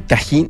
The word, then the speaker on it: tajín: Eh, tajín, se pronuncia tajín, pero es el plato más típico de Argelia.